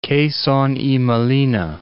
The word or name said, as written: Quezon y Molina